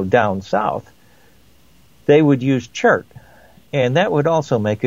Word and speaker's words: chert: Well, how do you pronounce it anyway? – Chert. Okay. C-H-E-R-T.